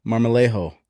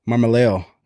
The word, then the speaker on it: Marmolejo: Today, I'm here with my good friend, Caleb Marmolejo. Marmolejo.